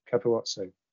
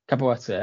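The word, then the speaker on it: Capuozzo: Oh, and final highlight of that game, the commentator referred to Ange Capuzzo. Am I saying that right? The Italian fullback, Capuazzo. – I'd say you were. Capuazzo, that's the one, yeah.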